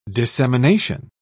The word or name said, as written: dissemination